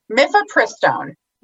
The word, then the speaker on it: Mifepristone: Mifepristone. – Listeners, she said it right. I said it kind of, you know, not all the way right.